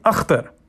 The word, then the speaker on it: Akhtar: "So Ak, like octopus?" – "That works." – "But is that how you say it? Where you're from? Where are you from?" – "Pakistan." – "Pakistan. – And we pronounce the name differently there." – "I'm talented. I can say it right." – "So we say Akhtar." – Father reverted to the native KH guttural sound that no white American in his experience had ever been able to master. There was a moment's silence on the other end of the line.